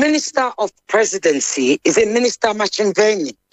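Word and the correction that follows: Ntshavheni: Did I pronounce it right? – Nchaveni. – Did I pronounce it right? – Minister in the Presidency, Nchaveni, yes.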